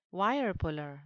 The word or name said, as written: wirepuller